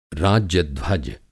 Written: राज्य-ध्वज

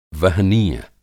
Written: वहनीय

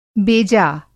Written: बेजा

